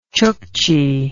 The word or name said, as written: chuchchi